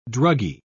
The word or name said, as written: druggy